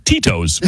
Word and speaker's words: Tito's: Yeah, it says advertiser's name, Tito's. Pronunciation, Tito's. T-E-E-T-O-E-S.